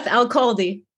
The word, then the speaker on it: Elkhaldy: Elkhaldy.